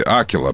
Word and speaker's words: Акила: Акила, правильное ударение.